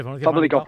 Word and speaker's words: Famalicão: No sé si conoces mucho, poco, pero seguro que algo, a Justin De Haas, que viene de allí, que viene de Famalicão. No sé si lo pronuncio bien. No sé si es Famalicão, Famalicão. – Sí, sí, sí. Famalicão. Haces muy bien.